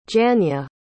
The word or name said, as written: janya